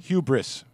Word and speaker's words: hubris: H-U-B-U-R-I-S? – No. – I could drink this, though. – H-U-B-R-I-S. – I could drink it. – Oh, there's no vowel between those two consonants. – I think I've been mispronouncing it my entire life. – Hubris.